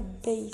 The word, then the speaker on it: base: Por último, la palabra base nosotros la pronunciamos tal cual se escribe y en inglés se pronuncia base al contrario al español, no pronunciamos la A y lo cambiamos por una E y I latina y aparte no pronunciamos la E final.